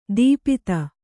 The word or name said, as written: ದೀಪುತ